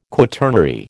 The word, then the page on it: quaternary: American English